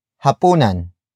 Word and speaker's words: hapunan: And that is hapunan. Hapunan. Hapunan. Hapunan is spelled as H-A-P-U-N-A-N.